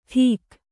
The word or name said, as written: ಟಿಕ್